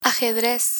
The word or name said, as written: ajedrez